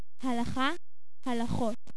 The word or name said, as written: Halakhah